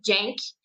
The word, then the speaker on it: Genk: São ele, o Genk, eu vou seguir a pronúncia como diria o, o Rafa e o Túlio. O Tulinho disse que aqui antes das transmissões ele sempre dá aquela olhada no, no Google para aprender a, a, a, pronúncia. Eu fiz isso. Então, vou no Jenk, da, da Bélgica, e também o Bruja, que é um outro clube belga.